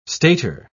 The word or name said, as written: stator